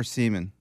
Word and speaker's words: Siemian: Yes, Trevor Siemian. – Yes, it's pronounced Siemian.